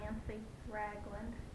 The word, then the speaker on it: Nancy Ragland: I prefer to go by Nancy Ragland. That's N-A-N-C-Y-R-A-G-L-A-N-D.